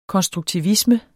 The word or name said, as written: konstruktivisme